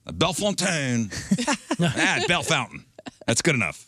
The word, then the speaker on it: Bellefontaine: Bell Fountain? – Yeah. – Like, we just took every French name and said, we refuse. – Like, it looks like it should be pronounced Bellefontaine. – Mm-hmm. Oh. – We say Bell Fountain.